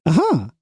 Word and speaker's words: Aja: What's his name? – Aha, A-J-A. – Aha. – Aha. – There you go. That's how it's pronounced.